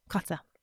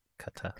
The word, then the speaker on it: Qatar: Qatar. – Thank you. – No one pronounces it right. No one does. – Qatar.